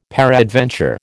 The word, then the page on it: peradventure: American English